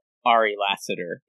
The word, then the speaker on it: Ari Lassiter: And if you're going to be standing a Ticos player on Montreal as well, Ari Lassiter is how his name is pronounced.